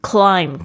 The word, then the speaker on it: climb: climb 大家可能比较知道爬山的这个爬山，m b 呢，这个 c l i m b 这个 b 也是不发音的哈。